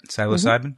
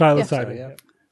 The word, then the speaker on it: psilocybin: Psilocybin? Mm-hmm. Am I pronouncing that correctly? – Psilocybin. – Psilocybin.